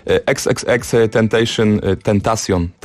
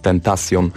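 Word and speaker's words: XXXTentacion: XXX Tentacion, tak to się wymawia, zawsze mówiłem Tentacion, ale to jest Tentacion.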